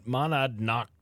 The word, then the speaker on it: Monadnock: A critically injured hiker was rescued from a very steep terrain along the Metacomet Monadnock Trail.